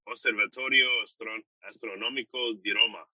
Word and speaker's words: Osservatorio Astronomico di Roma: He's a researcher at INAF, the Osservatorio Astronomico di Roma.